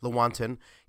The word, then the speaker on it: Lewontin: Let me give you another quote from a guy named Richard Lewontin, or Lewontin. I've heard it pronounced both ways, but I'm pretty sure it's Lewontin.